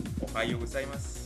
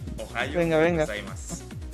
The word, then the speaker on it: Ohayou gozaimasu: Que se pronuncia Ohayou gozaimasu. – ¿Qué dijo? – Está más difícil. – Ohayou gozaimasu. – Ohayou gozaimasu. – Exacto.